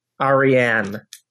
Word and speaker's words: Ariane: But is that how it's pronounced? – Arian, Ariane. – Ariane? Okay.